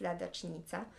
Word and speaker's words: ladacznica: That will be probably easier, because yes, in Polish, the fan page is pronounced Ladacznica, which is...